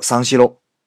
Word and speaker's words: 三四郎: 啊，这是一个谐音，谐的呢是日本传奇柔道家资三四郎，三四郎这个名字在日语的发音里面呢叫桑西喽。